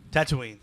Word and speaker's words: Tatooine: How many suns does Luke's home planet of Tatooine, is that how you say it, Tatooine? – Tatooine.